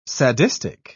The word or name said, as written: sadistic